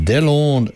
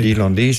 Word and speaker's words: Delondes: En het wordt bezongen of gezongen door de Delonde. Nee, dat moet je anders uitspreken, hè? De Delondes, hoe zei je daar laatst ook alweer? – Ja, de Delondes, dat is ook in Pascal.